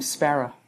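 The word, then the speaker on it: Szpara: Might move over to my married name, Sparrow.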